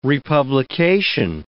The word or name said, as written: republication